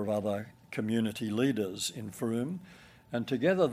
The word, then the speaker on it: Frome: Frome spelled F-R-O-M-E, but if you want to look it up, pronounce Frome.